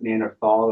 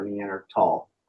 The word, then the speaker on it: Neanderthal: So there's two ways to pronounce Neanderthal. You can pronounce it Neanderthal or Neandertal.